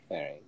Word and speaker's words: Ferenc: F-E-R-E-N-C was his first name. – Ferenc. I would say Ferenc, yeah.